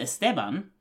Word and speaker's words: Esteban: There's never an accent over the A in that name. It's Esteban. Like, it's not even how an English speaker pronounces it. An English speaker puts the emphasis on the E. – Esteban. – Right. That's how it's pronounced to an English speaker. In Spanish, it's Esteban.